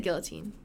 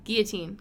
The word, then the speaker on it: guillotine: You said it right. – Oh, I did. – Guillotine. Guillotine. Right. I said it how it's spelled.